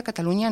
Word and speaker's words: Catalunya: Y, por ejemplo, la palabra Cataluña, con Ñ, se escribe en catalán como Catalunya. – Con Y. – Ojo, con Y, pero ojo, eso es en la escritura, que luego se pronuncia Cataluña normal.